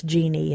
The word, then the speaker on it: Jeanne: In fact, some of the slave records spell it Marie, J-O-H-N, but the French name was J-E-A-N-N-E, Marie Jeanne. – Right, which some of us pronounce Jeannie in America.